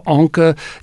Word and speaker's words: onca: The specific epithet is Greek for claw, onca. You know, that in Greek, the C, if that C is followed by the vowel A, then you got to pronounce it like a K.